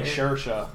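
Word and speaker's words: Saoirse: I finally got around to catching Byzantium, starring Swarcy Runnin, or whatever you say her name. I don't even know, and I don't know. It's like Shersha. It's not how it's spelled.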